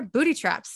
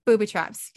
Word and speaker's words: booby traps: Booty traps. There you go. Booty traps during the whole thing. Like there's no. Booby traps. Booby traps is the real one, right?